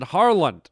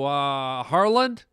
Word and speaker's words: Harland: And so I've known this guy for a number of years, and he asked me my name once, and I said, Harland. And he's like, what was that? And I go, Harland? What? How you say it? Harland. He goes, okay, Carlos. And I was like, no, Harland. He goes, oh, okay, good, Carlos, yeah.